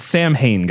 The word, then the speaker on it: Samhain: No, it's Samhain, guys. They wouldn't spell it like that if they wanted you to pronounce it Samhain.